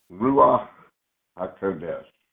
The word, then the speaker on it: Ruach HaKodesh: Because Jesus succeeded in holiness, He was able to send the Ruach Kadosh. Kadesh? How do you say it? – Ruach HaKodesh.